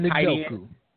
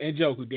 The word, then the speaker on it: Njoku: I don't know how to say his name, but you know who I'm talking about. – David Njoku. – Yep, Njoku. There you go.